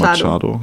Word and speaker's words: Machado: Machado so spricht man Franz äh, Spanisch.